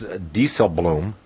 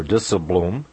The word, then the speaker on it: Dijsselbloem: The head of the Euro group over there, Jerome, and it's actually pronounced Dijsselbloem or Dijsselbloem.